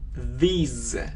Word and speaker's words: these: Здесь это произносится как z. Z. И долгий звук i. These. These. А здесь краткий звук i и просто s.